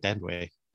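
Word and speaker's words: Thandiwe: Yeah, it's, I mean, I thought, is it Thandaway? Thandaway name? Or? – Yeah, I think Thandaway. – That's how it's pronounced.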